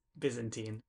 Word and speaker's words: Byzantine: Uh, folks, I just want to take a soft pause to note that not only did say Kafka esque, he also said biz- Byzantine. Byzantine. I pronounced it wrong.